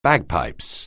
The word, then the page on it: bagpipes: anglais